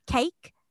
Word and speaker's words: Cayc: Imi May is the founder of the Cake and the Yoga Club, cake pronounced cake, but spelt C A Y C.